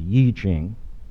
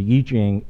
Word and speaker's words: I Ching: Another one you may have encountered is the Yi Ching. Looks like it's spelled I Ching. Well, the Yi Ching is the ancient important text on the subject of change.